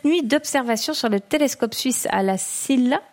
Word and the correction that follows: La Silla: À la SIA.